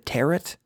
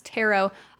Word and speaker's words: tarot: Also, interesting, not pronounced tarot. – No. – For a very long time, even when we were doing this podcast, I always, in my head, said tarot, like carrot. – No, it's tarot.